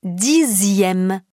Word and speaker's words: dixième: Ainsi, on écrit second, S-E-C-O-N-D, mais on prononce second. On écrit dixième, D-I-X-I-E, accent grave, M-E, mais on prononce dixième. Sixième, S-I-X-I-E, accent grave, M-E, mais on prononce sixième. Deuxième, D-E-U-X-I-E, accent grave, M-E, mais on prononce deuxième.